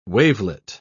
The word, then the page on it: wavelet: British English